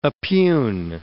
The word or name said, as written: oppugn